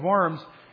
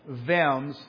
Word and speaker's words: Worms: I was in high school and in Vems, but it's spelled Worms.